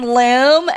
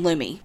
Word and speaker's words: Lumi: One time, I recommended Lumi to someone, and they're like, you mean Loom? And I was like, no, I mean Lumi.